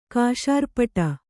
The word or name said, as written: ಕೇಸರಿಪೀಠ